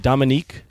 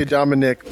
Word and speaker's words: Dominique: Is it Dominique? – Yep, Dominique. A lot of people say Dominic, but it's Dominique.